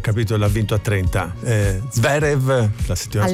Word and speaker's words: Zverev: Se mi correggevo, non è Zverev ma Zverev, allora ha fatto tutta la settimana lo scioglilingua, Zverev, Zverev, sentite come esce adesso come una grondaia, vai benissimo, Zverev, esatto, è come la grondaia Zverev, proprio lo mettiamo sullo stesso piano dell'addominale così come tu lo pronunci e con lo pronunci di Bellingham.